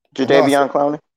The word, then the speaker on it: Jadeveon Clowney: if I say his name, Jerron Clowney. Did I say his name right? – Jadeveon Clowney.